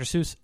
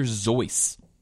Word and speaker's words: Seuss: It's not Dr. Seuss. It's Dr. Zoice. That's how it's supposed to be pronounced. – But it's a pseudonym, so I guess he can say it's Zeus. – He said it was Zeus. – Whoa. – Yeah, he said it rhymes with voice or choice.